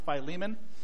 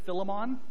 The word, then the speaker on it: Philemon: First of all, it is pronounced Philemon. Some people say Philemon. I don't know why, but it's Philemon. Maybe it's Philemon for you, but for this morning, I'm going to say Philemon.